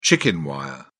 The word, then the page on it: chicken wire: British English